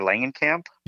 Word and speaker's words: Langenkamp: And I didn't know that, you know, the mother was keeping this a secret from Heather Langenkamp. – Yes. – Is that how you say it? – Yes.